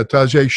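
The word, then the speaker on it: Tajae: I think you say it, Tajay Sharp. Is that how you say it? – Mm-hmm, Tajay. Mm-hmm. – Yeah, Tajay Sharp.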